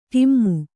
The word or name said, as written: ಡುಮ್ಮು